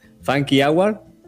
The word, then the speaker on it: Funky Hour: Eh, no sé si la pronunciaré bien, ahí nos dirán ellos. Funky Hour, ¿está bien? – Perfecto.